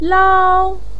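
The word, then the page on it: lao1: pinyin; tone 1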